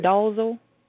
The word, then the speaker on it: Dolezal: Well, before we go into that aspect of the conversation, I want to read, because she has Rachel Dolezal. Is that her last name? Is that how you say it? – Sounds good to me. – Dolezal.